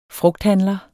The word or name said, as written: frugthandler